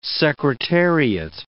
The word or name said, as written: secretariat